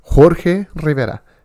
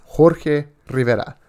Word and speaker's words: Jorge Rivera: my name is Jorge Rivera," just like that, just like it was meant to be said. Yes, Jorge Rivera. You know, with the the hard J, the R's, and everything, because that's my name.